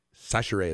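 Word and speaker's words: Chachere: Sachere is how you pronounce it.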